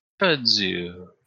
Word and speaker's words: kudzu: It's K-U-D-Z-U. – Kudzu.